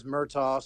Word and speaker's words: Murtaugh: We had some Murrays, but my name is Murtaugh.